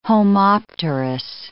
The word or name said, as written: homopterous